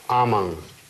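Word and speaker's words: amam: «Аман». Вот то, что «м» в конце, это читается не «м», а просто в нос.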